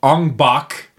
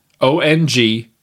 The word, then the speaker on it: Ong-Bak: It's called Ong Bak.